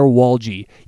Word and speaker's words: Walje: Or you can follow me and ask me individually, at Tyler Walje. You spell that last name, W-A-L-J-E.